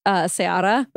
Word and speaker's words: Ceará: Fun fact, like C-E-A-R-A. It's pronounced Ceará, but it's like a place in Brazil.